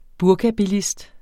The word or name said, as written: burkabilist